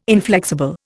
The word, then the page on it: inflexible: Indian